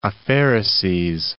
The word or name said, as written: aphaereses